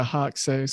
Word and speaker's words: Ajax: I could see over in this match just because, look, I mean, Ajax scored or a uh, Hoxay, sorry, that's the proper pronunciation.